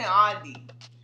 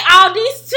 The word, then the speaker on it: Aldi: It was like the S in Illinois is silent and the S in Aldi is invisible because there's no S in Aldi. – True, but she say Aldi's too.